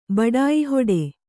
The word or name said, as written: ಬಡಾಯಿಹೊಡೆ